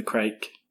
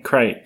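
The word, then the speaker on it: craic: You're just having the crack. – It's kind of misleading. – It's spelled like that. I keep on saying crake.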